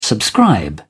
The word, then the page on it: subscribe: British English